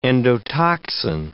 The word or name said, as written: endotoxin